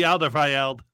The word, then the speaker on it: Alderweireld: Antwerp's at least got Toby Alderweireld.